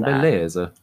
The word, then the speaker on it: Beleza: The next one's title is You Complimented My Car Outside Beleza Coffee, Beleza, B-E-L-E-Z-A. I'm not sure how you pronounce that. – Beleza.